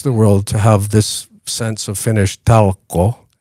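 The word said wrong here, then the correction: talkoot: how do you say that? – Talkot. – Talkot. – There's a T Talkot. – In the end. – Oh, Talkot. Yeah.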